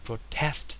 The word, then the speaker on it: protest: Protest 抗議、これは明前動語の単語で抗議するっていう場合は同じするで Protest っていうふうに後ろにアクセントがずれます。